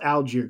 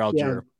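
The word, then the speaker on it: Allgeier: And that's big for both these teams, you know, Abram Smith and it's pronounced Algier, correct? Algier, yeah, Tyler Algier.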